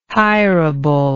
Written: hirable

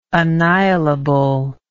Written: annihilable

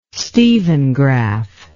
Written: Stevengraph

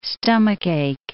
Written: stomachache

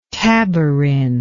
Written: taborin

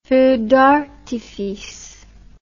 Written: feu d'artifice

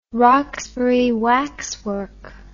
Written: Roxbury waxwork

